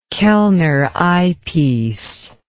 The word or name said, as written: Kellner eyepiece